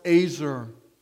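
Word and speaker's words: ezer: That Hebrew word is pronounced azer. Azer.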